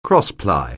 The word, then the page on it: crossply: anglais